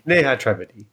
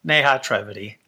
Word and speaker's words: Neha Trivedi: I always call her Neha, but that's not quite how to say it. Trivedi. Neha Trivedi. How would you say it? – Neha Trivedi. – Neha Trivedi. I've been saying it wrong for years, and she's too polite and too nice to correct me every time.